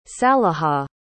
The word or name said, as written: salihah